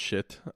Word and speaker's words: Schiit: And it's actually pronounced shit.